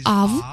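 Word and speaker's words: Ав (אב): И на самом деле в иврите это uh, произносится как Ав, потому что здесь нет дагиша.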